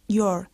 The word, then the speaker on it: your: Y observad que el adjetivo posesivo your se pronuncia igual que la forma contraída de you are, your.